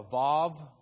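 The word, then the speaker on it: vav (ו): That is a wa or a vav. In modern Hebrew, they would pronounce that with a V. We think most of the time in ancient Hebrew, they pronounce it with a W.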